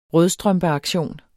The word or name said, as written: rødstrømpeaktion